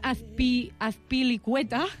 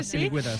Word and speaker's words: Azpilicueta: Eric García, espereu, és que vull pronunciar bé el nom. César Azpilicueta. – Sí, senyora, així, César José, Azpilicueta, sí.